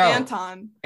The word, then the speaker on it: Anton: I love that you keep saying his name wrong. – Isn't his name Antoine? Or no. – Anton. – Anton. Oh my God. Where did the W come from?